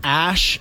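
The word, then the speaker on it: Æ: And then they're trolling Twitter with this because someone figured out that the AE is a, it's called an E ligature. I don't know what that is, but yeah. – It's pronounced Ash.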